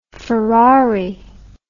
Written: Ferrari

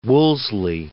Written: Wolseley

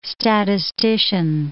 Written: statistician

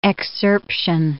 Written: excerption

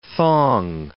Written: thong

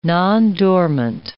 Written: nondormant

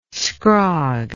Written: scrog

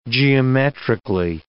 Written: geometrically

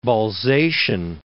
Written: Balzacian

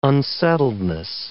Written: unsettledness

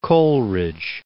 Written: Coleridge

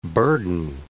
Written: burden

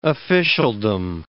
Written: officialdom

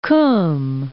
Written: Kum

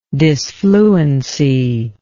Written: dysfluency

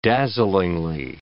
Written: dazzlingly

